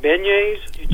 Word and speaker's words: beignets: You're talking about beignets. – That's it. I don't know the French pronunciation. I was going to say baguettes, but you're right. That's the way to pronounce it.